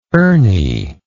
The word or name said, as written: ernie